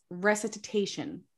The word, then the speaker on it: recitation: Oh yeah, his recording of the song includes Janice Spence's recitation. So I'm going to read a recitate. Is that how you say it? – Recitation. – Recitation. Add that to my mispronounced words.